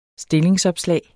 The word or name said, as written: stillingsopslag